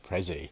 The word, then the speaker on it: Prezi: Prezi doesn't have a T in the pronunciation, or maybe that's a North American pronunciation, Prezi.